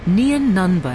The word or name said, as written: Nien Nunb